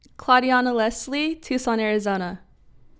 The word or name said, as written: Claudeona Lesley